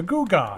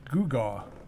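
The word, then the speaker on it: gewgaw: A googa.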